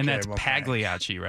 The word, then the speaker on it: Pagliacci: I thought you said Pagliacci had a deal. – Oh, there it is, Pagliacci. Soft G, bro.